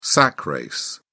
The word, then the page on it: sack race: British English